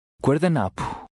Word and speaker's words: guardanapo: The final P-O is almost silent, like a breath. – Guardanapo.